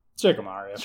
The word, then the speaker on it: Shikamaru: How do you pronounce it? – Shikamaru.